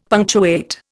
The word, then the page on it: punctuate: Indian